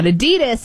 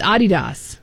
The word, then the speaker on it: Adidas: It's not Adidas. – Oh, yeah. Adidas. – It's Adidas.